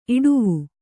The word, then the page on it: ಇಟ್ಟೇವು: itṭṭēvu